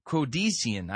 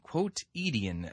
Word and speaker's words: quotidian: Its campus was relentlessly, this is an interesting word, quotidian. I'm not even pronouncing that. Q-U-O-T, quotidian, I-D-I-A-N, designed to suggest the shopping malls and office parks where members spent their time during the week.